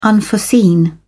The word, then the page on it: unforeseen: British English